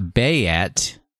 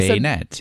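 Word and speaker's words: Banette: Baynet. – It's not Bayet. – No, I Baynet.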